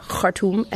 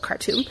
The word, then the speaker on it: Khartoum: It's pronounced Khartoum and not Khartoum, but they meet here.